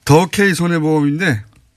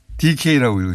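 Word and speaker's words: 더K손해보험: 아, 더 K 손해보험인데 DK라고 읽으셔가지고 어, 예, 네.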